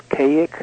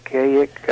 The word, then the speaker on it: Kazik: The magic word is Kaik. It only worked for him, but it's spelled K-A-Z-I-K, and the question is, well, then why is it pronounced Kaik? All of those things are characteristic of magical conjurations.